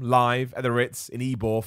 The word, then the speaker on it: Ybor: It's pronounced Ybor.